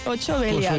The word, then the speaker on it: 8 Belial: Segons com el pronuncien, al final de les cançons és 8 Belial.